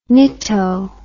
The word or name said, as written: nitto